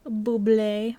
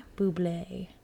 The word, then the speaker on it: Bublé: So when Keith picked up the phone, it was playing Everything by Michael Bublé, which happened to be their wedding song. – Bublé. – Bublé. I never say it right the first time, so I'm really surprised I just did there.